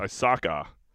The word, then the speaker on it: Osaka: Naomi Osaka, or how do you say this girl's name? Naomi Osaki. – Osaka cries during a match because fan yells, "You suck," when she's about to serve.